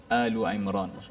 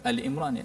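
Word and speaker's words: Alu Imran (آل عمران): Uh, so kita menyemak dua ayat ini iaitu ayat ke-38 dan 39 surah Alu Imran. So bacanya Alu Imran. Ada orang baca Al Imran ya. Itu tidak tidak tepatlah.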